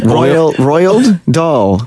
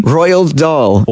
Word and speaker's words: Roald Dahl: Roald Dahl. – Oiled Dahl. What am I not getting? Say it again. – Roald Dahl.